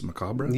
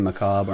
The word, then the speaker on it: macabre: Suspense, macabre. – Yeah, you can either say macabre or macabre.